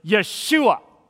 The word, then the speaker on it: Yeshua: Or if we could make it a little bit more Hebrew, we pronounce it Yeshua. Yeshua, Y apostrophe S-H-U-A.